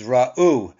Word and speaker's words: ra'u: That's actually the accent is on, speaking of accentuate the positive, the accent is on the last syllable, which is ra'u.